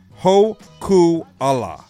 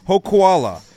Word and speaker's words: Hokuala: Hokuala. Just like koala. So, Hokuala.